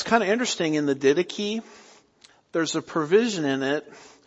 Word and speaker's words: Didache: By the way, don't pronounce that the Didache. I was talking to one of my friends on the phone and he kept calling it the Didache. And no, that's not pronounced the Didache. It's the Didache.